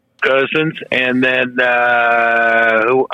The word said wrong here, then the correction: Mariota: or how you pronounce his name, from Oregon, Mariotti. – Oh, yeah, Mariota, yep.